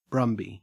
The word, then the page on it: brumby: Australian English